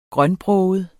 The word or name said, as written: grønbroget